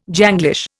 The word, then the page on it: Janglish: Indian